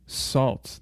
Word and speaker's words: Sault: Coming up next, we have a couple of tracks from Salt. That's spelled S-A-U-L-T.